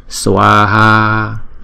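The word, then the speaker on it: svāhā: Swaha, chữ Swaha là hai âm và chúng ta mỗi âm đọc hai nhịp bởi vì chữ A có dấu gạch ngang trên đầu. Swaha.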